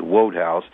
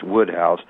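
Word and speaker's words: Wodehouse: I thought that was Wode House. And somebody who knew those things, you know, explained it to me that, it's actually pronounced Woodhouse.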